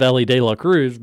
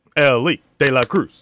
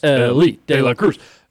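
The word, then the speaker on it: Elly De La Cruz: You said it wrong. Ellie De La Cruz. – Ellie De La Cruz.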